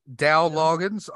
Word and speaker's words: Dowell Loggins: Dowell Loggins, also a Patton Oswalt impersonator.